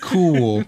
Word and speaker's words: KOOL: Cool. – Fucking Joe Camel. – Spelled like the fucking cigarettes. Cool. K-O-O-L.